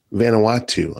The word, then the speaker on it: Vanuatu: About 20% of children in Vanuatu, am I saying that right? – Vanuatu. – I'm sorry.